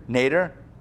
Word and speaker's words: nadir: The nadir? How do you pronounce that? – Nader? – Like Ralph? – Except it's an IR. – Say it again? – N-A-D-I-R.